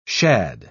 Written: shad

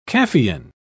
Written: caffeine

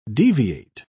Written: deviate

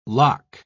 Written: lock

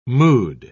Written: mood

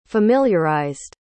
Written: familiarized

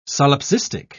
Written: solipsistic

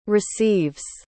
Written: receives

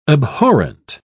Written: abhorrent

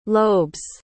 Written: lobes

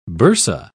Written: bursa

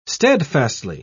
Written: steadfastly

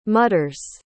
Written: mutters

